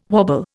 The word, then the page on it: wobble: Indian